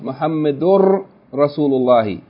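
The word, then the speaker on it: Muhammadur Rasulullah (محمد رسول الله): The point that we made in the notes is that you see on the bottom of the page it says Muhammadun Rasulullah is pronounced Muhammadur Rasulullah with the noon assimilated into the N assimilated into the R.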